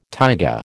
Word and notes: taiga: American English

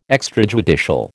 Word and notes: extrajudicial: American English

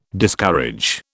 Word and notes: Discourage: British English

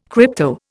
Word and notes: crypto: Indian

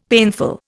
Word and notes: painful: Indian

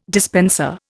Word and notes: dispenser: Indian

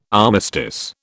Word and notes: armistice: British English

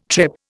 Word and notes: trip: Indian

